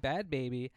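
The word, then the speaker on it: Bhad Bhabie: First red flag, okay, is that Bad is spelled B-H-A-D, uh-huh. – And Baby is spelled B-H-A-B-I-E. – That sounds like a drug. – Bad Baby with an H? – Bad!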